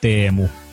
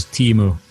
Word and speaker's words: Teemu: The way how you pronounce it in correct Finnish is Teemu. – I'm not going to be able to recreate that. – Yeah, but you can do the English version, which is Teemu.